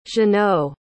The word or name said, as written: jeannot